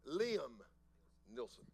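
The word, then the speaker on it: Liam Neeson: And it's the story of this man, Leman Nielsen. That's the way you say his name? Liam Nielsen. What's his last name? Neeson.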